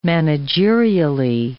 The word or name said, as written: managerially